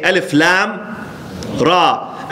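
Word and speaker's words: الر: Okay, we don't say ألم ألر. We say ألف لام را. ألف لام ميم. We write it connected, but we pronounce it separated.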